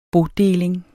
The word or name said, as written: bodeling